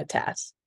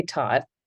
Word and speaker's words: Tat: Americans say Tot, which is strange to me, but I'll take it. – But that's T-O-T. You're T-A-T. That's a Tat.